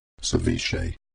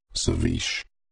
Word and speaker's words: ceviche: The cold fish dish, for instance, is properly pronounced as ceviche, but 61% of human readers in New Zealand say ceviche or ceviche.